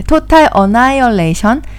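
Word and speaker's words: Total Annihilation: 어, 유명한 게임으로는 신선도, 토탈 어나이러레 발음이 안 돼. 토탈 어나이얼레이션, 성경 전설, 라그나 로크와 같은 게임을 소유하고 있습니다.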